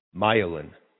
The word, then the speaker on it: myelin: pointed out to me that I'm mispronouncing Myelin, and that the correct way to pronounce it is to accent the first syllable, Myelin.